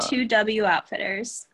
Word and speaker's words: 2W Outfitters: 2W Outfitters.